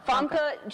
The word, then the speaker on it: Famke: Somebody told me it was Famke. – No, definitely not Famke. Famke Janssen.